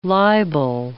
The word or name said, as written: libel